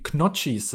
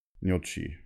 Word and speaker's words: Gnocchi: nee, das, das Doppel-C spricht man Chi aus, meine ich. Gnocchi. – Äh, Aber das, das G ist stumm.